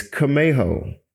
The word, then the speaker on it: Camejo: People look at my last name, Kameho, and they're like, first of all, it's kind of hard to pronounce when you first see it, right? So it's Kamejo, C-A-M-E-J-O. It's actually a Hispanic last name.